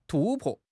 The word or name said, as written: 突破